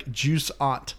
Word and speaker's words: Jusant: How do you pronounce the game? – Jusant. – Okay. It sounded like you were saying like Jusant. – Jusant. – You know, it's juicy. It's Jusant.